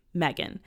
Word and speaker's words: Megan: So my entire life, I've grown up with my name, Megan. And it's spelled M-E-G-A-N, right?